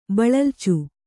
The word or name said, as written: ಬಳಲ್ಚು